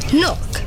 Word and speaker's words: knock: Nah, the K is silent. – Knock, and the door shall be opened unto you.